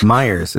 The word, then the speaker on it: Myers: He goes, because at the end of the day, you know, we're in the Mike Meyer business. I was like, Myers. It's actually Myers.